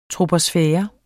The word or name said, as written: troposfære